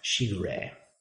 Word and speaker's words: shigure: The phrase in Japanese is "ware no mushi um, naru mushi shigaru." No, shigure which I butchered, it translates as within the chorus of inste- insect sounds, I am also an insect.